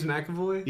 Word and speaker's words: McAvoy: Is that how you say his name? – No. – James McAvoy. – James McAvoy? – Yes. – Yes. – But there's not a Mac, it's just an MC.